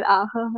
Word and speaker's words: Ragnar Ache: Tatsächlich Hauge und was auch immer ganz lustig war, ich hatte sehr lange Zeit Ragnar Ache falsch ausgesprochen gehabt, hat immer Arte gesagt und ja, seit neuestem weiß ich, dass es wohl jetzt offiziell Ache heißt.